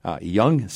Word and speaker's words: Jung: Young Seed, and that's spelled J-U-N-G, Young Seed, Seeds and Plants, and R.H.